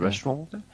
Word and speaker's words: restaurant: Notice how I didn't pronounce the T in the end of the restaurant.